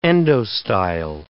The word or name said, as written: endostyle